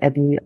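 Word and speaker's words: Aburi: Ich sitze in Aberi, Ghana, mit Aberi, aber geschrieben, aber Aberi ausgesprochen.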